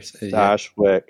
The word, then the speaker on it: Stashwick: Um, Stashwick, I think, or Stashwick.